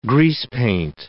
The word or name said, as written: greasepaint